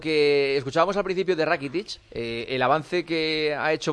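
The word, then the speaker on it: Rakitić: Rakitic, que se pronuncia así, Iván Rakitic, sobre cómo está ahora en el Barça.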